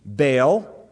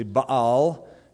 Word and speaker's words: Baal: He didn't really derive much worship from the Canaanites, because most of them spent all of their time worshiping Baal, as it mentions here, or that's our mispronunciation, it's actually Baal, and Asherah.